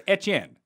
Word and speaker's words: Etienne: Now, it's no, because there shouldn't be. There shouldn't be a third syllable. There shouldn't be. It should just be Etienne if you're actually doing it right.